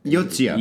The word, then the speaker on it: Yotsuya: And Yatsuya? – Yeah, is it? Yotsuya. – Yotsuya, pardon me, pardon my pronunciation.